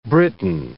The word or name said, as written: Briton